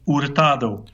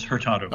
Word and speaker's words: Hurtado: It's a Spanish name, so if you were pronouncing it in its Spanish, it would be Hurtado. – Hurtado. Hurtado. – But the anglicized form is Hurtado.